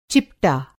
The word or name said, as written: चिपटा